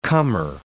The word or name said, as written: comer